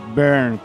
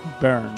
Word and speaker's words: Byrne: Una cosa, Byrne, pero es como Burn, pero raro. Byrne.